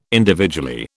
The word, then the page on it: individually: British English